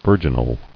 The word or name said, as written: Virginal